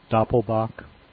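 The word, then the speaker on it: Doppelbock: I hear these styles mispronounced all the time, you know, Belgian styles too. It's Doppelbach. The brewery is Polliner, not Polliner.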